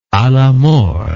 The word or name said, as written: alamort